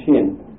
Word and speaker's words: shin: And if you put it on the sinister side, it is pronounced shin. And if you put it on the dexter side, it is pronounced sin.